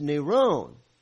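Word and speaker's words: Neron (Νέρων): Well, Greek Nero is spelled N-E, giving you the English letters, N-E-R, long O, omega, and then there's an N on the end. It's Neron.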